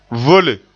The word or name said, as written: vole